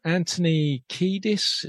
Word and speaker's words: Anthony Kiedis: But Anthony Kiedis, is that how you pronounce his name? – Yeah.